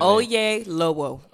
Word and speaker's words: Oyelowo: How do you say that? Oyelowo. – Oyelowo. Mm-hmm. Thank you for that.